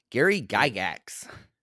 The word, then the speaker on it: Gygax: Gary Gygax.